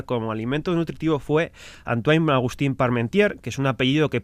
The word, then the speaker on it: Parmentier: La Parmentier. – Eso es. – Bien, lo dices así y mira. – Bien pronunciado, Miriam.